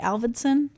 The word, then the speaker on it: Avildsen: This movie was directed by John Avildsen. Did I say that right? Avildsen? – Yes.